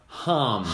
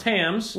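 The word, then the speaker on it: Hamm's: But there is nothing like a Homs. – Homs. It's pronounced Hams.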